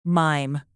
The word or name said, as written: mime